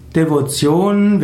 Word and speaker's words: Devotion: Devotion wird heute meistens ausgesprochen Devotion.